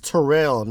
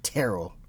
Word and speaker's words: Terrill: It's Terrill, not Terrill.